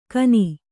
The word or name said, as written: ಖನಿ